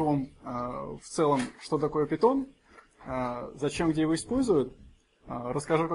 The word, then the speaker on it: Python: На самом деле читается как Python или Python в переводе на русский, но в русскоязычном сообществе уже принято говорить питон, поскольку так переводится из меня Python.